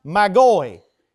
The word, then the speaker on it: magoi: First off, the word from which we translate into wise men is actually a Greek word that is pronounced magoi, M-A-G-O-I.